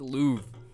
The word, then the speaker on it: Louvre: It's a Louvre. – Hang it in the Louvre? – Are you looking it up, seriously? – Huh? No. – What? Oh my God, he's clickety-clacketing away. Oh, it is Louvre.